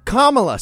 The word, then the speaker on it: Kamala: It's pronounced Kamala. Say it right, you ignorant rubes.